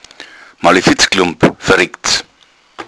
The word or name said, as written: malefitz